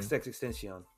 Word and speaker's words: XXXTentacion: How you say his name? – XXXTentacion.